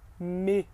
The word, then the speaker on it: mai: Os meses do ano, começando aí por janeiro, seria janvier, janvier, janvier, février, février, février, mars, mars, mars, avril, avril, avril, mai, me, me. Olha aí que um som de A com I fica E, me.